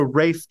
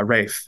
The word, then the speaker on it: Rafe: And I think, so Rafe Judkins, who is the showrunner, has outright said... – Is that how you say it? – It is. Yeah, Rafe.